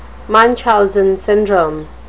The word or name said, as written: munchausen syndrome